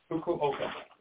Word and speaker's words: Fukuoka: We're going to go over to Fukakov and Kishinu. – Fukuoka.